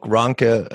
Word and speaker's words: Ranke: And you talk about in your book, Ranke. I'm not sure if I'm pronouncing it correctly, but near enough. – Near enough. Thank you.